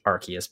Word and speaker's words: Arceus: And as I understand it, it is actually pronounced Arceus, because I have heard a lot of different pronunciations this year.